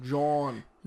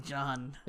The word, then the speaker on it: jawn: How do you spell it? – J-A-W-N. – Oh, John. – John. – John. – Not like J-O-H-N, like not like John.